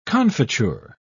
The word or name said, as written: confiture